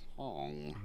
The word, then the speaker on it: Hoang: Hong or Hong? Year. – It's spelled H-O-A-N-G. So I don't really know how to pronounce Hong.